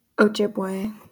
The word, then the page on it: Ojibwe: /oʊˈdʒɪbweɪ/